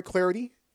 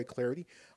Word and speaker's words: Clardy: I'm Troy Clarity. Follow me on Twitter at Troy Clarity. The last name is spelled C-L-A-R-D-Y at Troy Clarity.